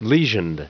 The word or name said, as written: lesioned